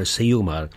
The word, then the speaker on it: Ceumar: If you're wondering, Seumar is spelled C-E-U-M-A-R, Seumar.